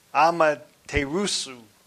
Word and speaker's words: Amaterasu: I actually got it written down here, but it's Amaterasu.